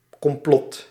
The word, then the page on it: complot: /kɔmˈplɔt/